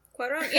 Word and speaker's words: Cuarón: Cuarón? – Cuarón.